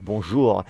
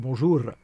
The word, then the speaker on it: bonjour: But sometimes also non-French speakers think that because we pronounce the R, you need to roll the R. So they would say like bonjour. Because they can't. – Mm. – But it sounds more Italian when you're rolling your R.